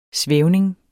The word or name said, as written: svævning